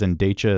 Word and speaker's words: Endecha: It's spelled E-N-D-E-C-H-A. I think they said it was Endecha. Is that what it was? – Yes.